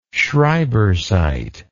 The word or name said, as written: schreibersite